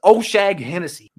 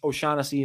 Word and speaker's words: O'Shaughnessy: James O'Shag Hennessy. Yes, I know it's pronounced O'Shaughnessy. It's James O'Shag Hennessy for me.